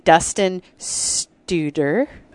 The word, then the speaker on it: Dustin Studer: And Dustin Studer.